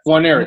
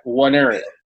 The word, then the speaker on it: Nwaneri: It's Nwaneri, so it's almost like a silent N almost. – Okay, Nwaneri.